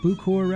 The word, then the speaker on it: Bucor: Then we had Ronnie Mack with Twisted Little Telephone Line on Bucor Records. Bucor. B-U-C-O-R is how you spell that for those keeping track at home.